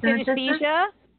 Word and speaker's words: synesthesia: synesthesia? – Yes, thank you.